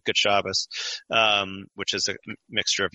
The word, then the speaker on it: Shabbos: They say Shabbos, which notice it shifts the T sound to an S, and it shifts the syllable emphasis to the first syllable. And it sounds more like German. It sounds more like, you know, the languages that Yiddish was influenced by, Shabbos.